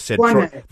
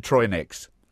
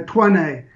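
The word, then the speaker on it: Troinex: I was curious about the pronunciation of it, and you can't trust those pronunciation guides online, because Tuane. – they all said Troy next.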